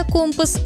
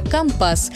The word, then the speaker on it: компас: Так и получилось, что одно слово живет сразу в двух вариантах – сухопутное – «компас» и морское – «компас». И в этом есть своя профессиональная логика. На флоте язык всегда чуть более строгий, точный и свой. И если услышали такое ударение, это уже не ошибка. Это просто другой, профессиональный язык.